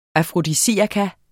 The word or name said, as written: afrodisiakum